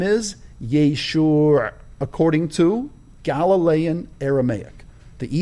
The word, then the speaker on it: Yeshua: Shoe, the thing you wear on your feet, Yeshua. And so at the end, it's this, Yeshua, was the Galilean Aramaic way of pronouncing the name of Jesus, peace be upon him.